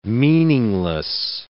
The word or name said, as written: meaningless